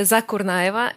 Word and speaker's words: Zakurnaeva: My name is Maria Zakurnaeva.